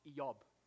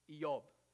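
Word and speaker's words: Eob (איוב): In Hebrew, Job's name wouldn't be pronounced Job, it'd be pronounced Eob. Eob.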